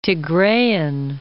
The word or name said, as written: Tigrean